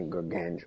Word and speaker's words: Gargano: So I'm making now official a non-sanctioned anything goes match at NXT TakeOver between Brian Pillman Jr. and Johnny Gargano. I can never pronounce his last name. – Gargano. – Gargano, there you go.